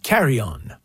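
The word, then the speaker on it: Carillon: That one just now, the Carry-On. It's a bit of a radio thing because, you know, it's pronounced in French.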